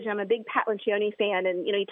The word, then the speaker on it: Lencioni: Lencioni.